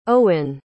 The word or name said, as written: eoghan